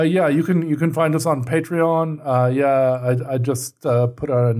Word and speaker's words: Patreon: I always mispronounce it as Patreon. Patreon, I guess.